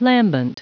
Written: lambent

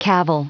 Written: cavil

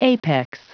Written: apex

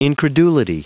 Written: incredulity

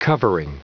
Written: covering

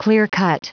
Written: clear-cut

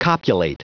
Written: copulate

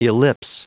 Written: ellipse

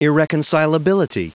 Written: irreconcilability

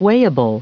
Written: weighable

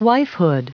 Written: wifehood

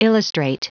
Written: illustrate

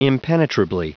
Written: impenetrably

